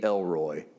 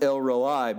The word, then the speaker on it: El Roi: She literally names him El-Roi, E-L-R-O-I, transliterated into English. I really want it to be Elroy, because it just seems like it would be great, wouldn't it? In my mind, when I read it, I go, she calls God Elroy. It's literally pronounced El-Roi, but it's in the English, you are the God who sees.